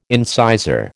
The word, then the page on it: incisor: American English